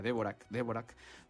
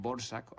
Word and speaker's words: Dvořák: Por cierto, siempre que hablo de este compositor me gusta deletrearlo porque se escribe D-V-O-R-A-C, Dvorak. Se leería, yo de hecho cuando era muy pequeño, antes de saber cómo se pronunciaba, se decía Dvorak. Realmente los checos dicen Dvorak o algo así,